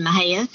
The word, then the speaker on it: Mexia: I grew up in Mahia, Texas. That is spelled M-E-X-I-A, so pronounced not anything like it is spelled.